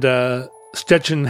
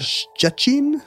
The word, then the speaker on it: Szczecin: Szczecin? – Szczecin. – I don't know. – It's got... It doesn't have any T's in it. It's spelled S-Z-C-Z-E-C-I-N.